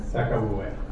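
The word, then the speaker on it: Sakauye: How do we say that name? – Sacabue. – There we go.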